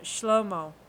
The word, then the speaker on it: Shlomo: So um, Solomon is pronounced uh, Shlomo.